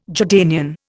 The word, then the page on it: Jordanian: Indian